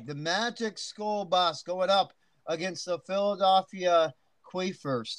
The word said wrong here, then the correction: Queefles: Do I pronounce that right? Queefers? – Queefles. – Queefles. – Queefles. – Queefles. Okay. – Like Nick Foles.